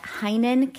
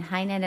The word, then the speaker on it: Heynen: You can also follow me on Instagram at Heinen Counseling and Coaching. Again, Heinen is spelled H-E-Y-N-E-N, Counseling and Coaching.